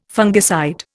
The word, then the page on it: fungicide: Indian